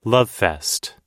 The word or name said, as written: lovefest